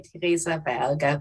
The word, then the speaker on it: Teresa Berger: So in German, I would say Teresa Berger, but that's how it for all right.